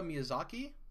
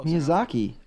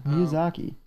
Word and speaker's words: Miyazaki: Hidetaka Miyazaki. Close. – Miyazaki. Now. Oh, yeah. Miyazaki.